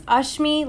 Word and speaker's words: Ashmi: I usually tell people it's Ashmi, like Usher.